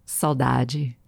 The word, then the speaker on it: saudade: And technically, it has one cognate of all the world's 7,000 languages, which is the Portuguese saudade. That was the Brazilian pronunciation.